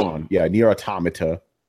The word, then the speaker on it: Automata: So other than Heroes of the Storm, I've been playing Nier Automata. – Yeah. – So man. – I love all the different pronunciations. It's automata, man. It's a play on the word automaton.